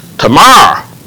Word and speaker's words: Tamar: 那我们首先讲棕树，棕树这个词有意思哈，它的希伯来文的发音就是塔玛 Tamar。